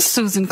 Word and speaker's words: Susan: Susan Clancy, S U S A N C L A N C Y.